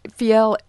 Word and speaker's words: Fjell: And we should tell folks, Judy Fiel is spelled F-J-E-L-L, if you want to go on the web and check her out. – Correct. – You'd never know that from the pronunciation. – Right. Fiel, and it's Nordic.